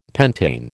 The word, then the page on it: pentane: American English